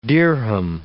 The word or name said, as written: dirham